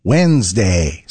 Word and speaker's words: Wednesday: It's midweek Wednesday, which of course we pronounce Wednesday. So why are we spelling it with a random D that nobody invited to the conversation? English is full of words like that. Take Colonel, for example. It's spelled C-O-L-O-N-E-L, but pronounced Colonel.